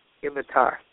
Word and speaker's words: Ibbatar: My name is Ibatar. Can you hear me okay? – I can. You said, can you spell that? Nibatar? – I-B-B-A-T-A-R. Ibatar.